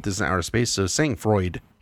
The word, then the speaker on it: sang-froid: So I'm pronouncing this Sangfroid. – Okay. – If this was French, it'd be sang-froid, I believe, which is cold-blooded.